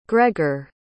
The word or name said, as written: greger